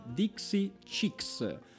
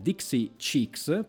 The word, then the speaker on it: Dixie Chicks: Eh, lo sapevo che non l'avrei detto, fatemelo ridire, sono le Dixie Chicks lo ridico, sono le Dixie Chicks,